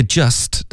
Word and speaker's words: just: 好，这句话开头 you've just，这个 just 的 t 的音呢，我们就彻底的省略，这个字太常见了。